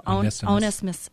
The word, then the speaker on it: Onesimus: When the Apostle Paul was in prison, he led a runaway slave named Onesimus, I can't even say it, to Christ. But then Paul sent him back to his master, even though, how do you say that? Do you know? – Onesimus.